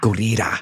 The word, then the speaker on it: Gurira: Gurira. Mm-hmm. She taught me how to say. She was like, double roll the R.